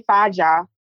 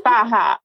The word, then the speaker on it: Faja: And the reason we're all calling it Faja, which by the way, if you say Faja, stop it. – No way. There's people calling that. By the way, I hear that. So Faja, that's the proper pronunciation.